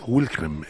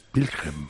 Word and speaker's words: Wilhelm: Esto es, vamos a ver, si tú hablaste con una chica alemana, debió explicarte que en alemán la W se pronuncia B. O sea, que no es Wilhelm, es Wilhelm.